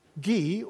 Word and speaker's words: Guy: Guy, or which is the French pronunciation, I understand, of the word guy, G-U-Y, was a five-year-old French lad.